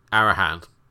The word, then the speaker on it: Arahan: And Aaron, I think, is that how you pronounce it? Arahan? Arahan.